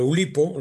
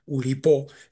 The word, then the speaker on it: Oulipo: Yo creo que en el Oulipo, lo estoy pronunciando a la española, Oulipo sería el nombre originario francés, en Oulipo coexisten esas dos vertientes como poco.